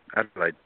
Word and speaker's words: Adelaide: I mean, Australian might call it Adelaide, but you said it perfectly, Adelaide.